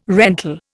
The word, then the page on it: rental: Indian